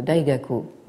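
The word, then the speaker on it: Daigaku (大学): En japonais, donc Da Xue donne Daigaku. Et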